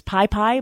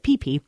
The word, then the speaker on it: Phi Phi: You would think reading it as Pi Pi, but they pronounce it Phi Phi.